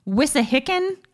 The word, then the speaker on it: wissahickon: probably going to butcher this name wissahickon creek sounds right to me